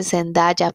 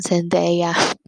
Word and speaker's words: Zendaya: Este, sí, yo aprendí también que no se dice Zendaya, perdón, Zendaya.